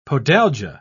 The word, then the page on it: podalgia: British English